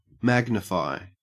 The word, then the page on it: magnify: Australian English